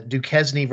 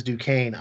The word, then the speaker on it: Duquesne: We certainly won't spend any more than we have to on Duquesne versus Duquesne. I'll just say the S is silent.